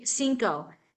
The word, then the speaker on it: cinco: But even in the word cinco, the two C's are pronounced differently in Spanish. – Right. And so it has to do with if it's at the beginning of the word or not.